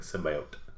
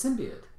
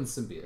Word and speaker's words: symbiote: It's symbiote. – Yeah, it's always been symbiote.